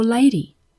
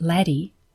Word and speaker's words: LADEE: Dear Cheap Astronomy, is it laddie or lady? And what is this mission all about? LADEE, pronounced laddie,